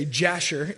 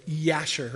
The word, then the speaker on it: Jasher: And I found out that there's an ancient Jewish document called the Book of if you're an American, you say Jasher. If you're Jewish, you would say Yasher, right? Because the J is pronounced with a kind of Y sound.